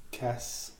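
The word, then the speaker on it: casse: så har man problemer med oxidativ gazé. Hvordan udtaler man det egentlig? Kasse. Kasse, Ja, det er fransk.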